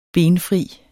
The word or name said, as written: benfri